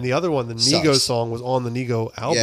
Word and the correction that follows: Nigo: I think it's Nigo.